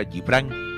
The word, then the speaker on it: Gibran: Y para esto voy a narrar un poema de Gibral Khalil Gibral, que dice así. Poeta del Líbano, por si acaso. Eh, en alguna en castellano se pronuncia Gibran Khalil Gibran